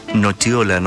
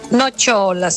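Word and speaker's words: nocciola: Y se pronuncia nochiola, nos dice por acá Daniel.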